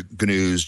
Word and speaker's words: Gnus: But I discovered that there was a news reader built into GNU Emacs called GNUs, G-N-E-W-S. This is before GNUs, G-N-U-S, which unfortunately is pronounced the same way, but G-N-E-W-S is long abandoned development, but G-N-U-S I've actually had some contribution for.